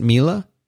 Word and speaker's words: Miele: Is that how you pronounce it, Miele? – Yeah, that's how they say it on their support line, which is the only reason I know that.